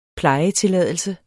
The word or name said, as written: plejetilladelse